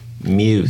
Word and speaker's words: Freiermuth: I don't mind that pick, as the early returns on Freiermuth are good, but I believe it's pronounced Muth.